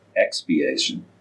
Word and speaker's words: expiation: And he said that's expiation. Pronounced that correctly? – Right.